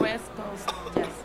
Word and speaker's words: West Coast Jazz: Espero haberlo dicho bien. West Coast. ¿O cómo se pronuncia esto? Es jazz de la costa del este, ¿no? – West Coast Jazz. – Ah, mira, lo dije bien.